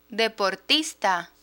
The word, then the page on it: deportista: Spanish